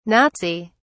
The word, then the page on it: Nazi: British English